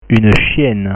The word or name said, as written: une chienne